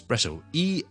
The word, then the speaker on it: espresso: Technically 嚟讲咧，呢个唔系真系一个英文字嚟嘅，系一个意大利文字咧。本身系 espresso 一个特浓嘅咖啡啊，我哋系 espresso，E S P R E S S O。